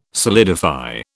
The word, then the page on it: solidify: British English